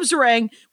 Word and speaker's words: Zarang: Caleb Zarang, which rhymes with meringue.